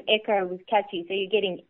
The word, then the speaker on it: ECOH: You've renamed the product to Echo. Is that how you pronounce it? E-C-O-H? – That's correct, Echo.